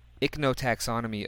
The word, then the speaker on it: Ichnotaxonomy: Number five, some of these things are touched on in what you just said, but Jeff Meldrum's paper, his Ichnotaxonomy of Giant Hominid Tracks in North America. Did I say that right? – Yeah. – Ichnotaxonomy? – I think you did.